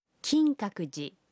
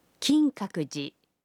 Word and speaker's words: Kinkakuji: Utasemaje ikiwa unataka kwenda Kinkakuji, hekalu moja lililopo Kyoto? Matamshi ni Kinkakuji. Kinkakuji.